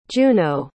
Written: juneau

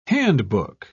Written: handbook

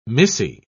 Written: missy

